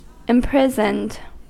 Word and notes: imprisoned: American English

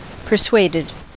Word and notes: persuaded: American English

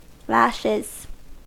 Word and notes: lashes: American English

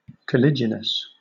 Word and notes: caliginous: British English

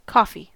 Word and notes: coffee: American English

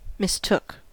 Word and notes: mistook: American English

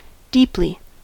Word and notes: deeply: American English

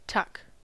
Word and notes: tuck: American English

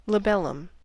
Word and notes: labellum: American English

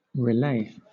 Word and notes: relay: British English

